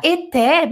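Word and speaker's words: étaient: Terceira pessoa do plural é, estavam e escreve fala eté mesmo, fala eté mesmo, eté. Fala eté dessa forma, eté, eté mas se escreve com A-I-E-N-T, terceira pessoa do plural, mas a pronúncia é E.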